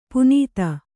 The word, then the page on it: ಪುನೀತ: punīta